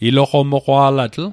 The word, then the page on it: iloqomoqwa'latl: Mi'gmaq